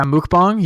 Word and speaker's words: mukbang: Mukbang. Yeah. I've done one. – Is that how you pronounce that? – Yeah. It's a mukbang.